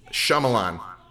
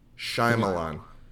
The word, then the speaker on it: Shyamalan: Shyamalan. – Okay, so I'm going to say M. Night. – Shyamalan.